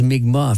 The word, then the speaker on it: Mi'kmaq: Puis, bon, avant, on disait Micmac, mais maintenant, on prononce Mi'kmaq, en fait, le nom de la Première Nation qui habite sur la péninsule depuis la nuit des temps.